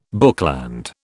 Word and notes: bookland: British English

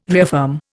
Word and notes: reaffirm: Indian

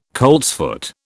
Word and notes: coltsfoot: British English

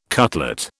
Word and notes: cutlet: British English

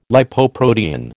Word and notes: Lipoprotein: American English

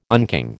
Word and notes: unking: American English

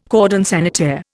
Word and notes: cordon sanitaire: Indian

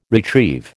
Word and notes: retrieve: American English

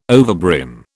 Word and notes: overbrim: British English

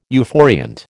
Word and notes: euphoriant: American English

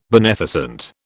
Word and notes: beneficent: British English

